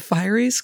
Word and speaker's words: Fieries: Back in the forest, Sarah encounters the Fieries, who, is it Fieries or Furies? Or I call them Fieries because that's how they pronounce it in the song.